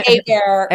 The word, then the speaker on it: Hebert: Bobby Hebert. – Hebert. – I see Hebert, right? We're Cajun French.